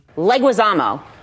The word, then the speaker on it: Leguizamo: The correct pronunciation is John Leguizamo. – Yeah, I say it Leguizamo.